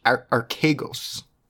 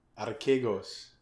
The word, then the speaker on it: Archegos: Well, first of all, I'm pretty sure it's pronounced Archegos, and you should know that because it's a Greek word. – Archegos.